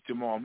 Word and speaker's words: Jamaal: Secondly, my name is Jamal Marvel, J-A-M-A-A-L, and then Marvel like Marvel Comics.